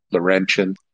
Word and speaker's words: Laurentian: The pronunciation is Laurentian.